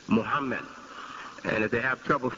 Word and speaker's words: Muhammad: And that was made possible by the phonetic spelling M-U-H-A-M-M-A-D. The previous spelling was also French-influenced spelling, M-O-H-A-M-M-E-D, M-O-H-A-M-M-E-D. And it's pronounced Muhammad. But the West pronounced it because of the spelling. They tried to pronounce it phonetically, and they said Mohammed.